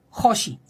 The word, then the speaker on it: Joshi: Hoshi cómo se escribe? Eh, con J es J O S H I Cosmetics.